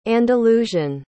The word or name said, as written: andalusian